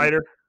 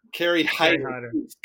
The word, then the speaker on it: Hyder: Hyder. – Kerry Hyder.